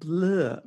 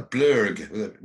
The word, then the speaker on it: Blurg: So what was your, I mean, because you're on, is it Blur Records, which was the same as the... – No, Blurg, with a G. We pronounce the G in Blurg.